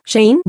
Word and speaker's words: Shein: La plataforma que utiliza Sachan pertenece a una marca china llamada Shein, que se pronuncia Shein- que ha atraído rápidamente a un ejército mundial de fans adolescentes en TikTok.